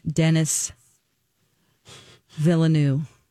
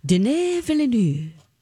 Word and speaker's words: Denis Villeneuve: Uh, I've been pronouncing his name Dennis Villeneuve. Okay. But we heard many times, fancier and fancier, as each award came out. Denis Villeneuve. Denis Villeneuve.